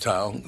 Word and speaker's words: Tau: Or is it Tau? T-A-U. – It's Tau. – Oh, in Greek, it's just Tau, yeah. – It's just Tau.